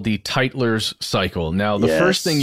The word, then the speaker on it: Tytler: you need to know that it's spelled TYTLER'S cycle.